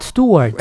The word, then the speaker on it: Stewart: Ah, Rod Stewart.